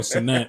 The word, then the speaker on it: Cenat: Or Sinat, excuse me. I don't want to get his name wrong. If you don't know, Cassinat was trying to give away PlayStations in New York, which ended up totally causing full mayhem and basically inciting a riot.